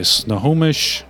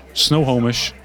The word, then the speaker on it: Snohomish: All right, so you are going to break into the Snohomish, Snowhomish, is how it's pronounced, Snowhomish, into the Snowhomish Medical Center.